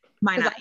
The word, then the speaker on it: Minot: I really don't know if Minot is the proper pronunciation, but that's the way everybody says it here.